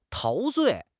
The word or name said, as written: 陶醉